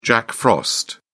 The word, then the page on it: jack frost: British English